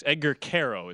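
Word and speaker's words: Edgar Quero: Edgar Cuero is how you pronounce Cuero.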